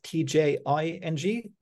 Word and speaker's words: Tjing: So the, the company that you have founded and uh, you're running, uh, spelled T J I N G, uh, I mm-hmm. think. So, uh, but pronounced Tjing, I heard.